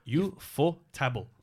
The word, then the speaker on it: Ufotable: And it is, and I'm going to say it wrong because I try and remember every time. Ufotable? Nope. – Ufotable. – Thank you.